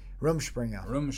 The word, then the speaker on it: Rumspringa: Rumspringa.